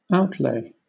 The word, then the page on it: outlay: British English